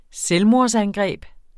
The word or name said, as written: selvmordsangreb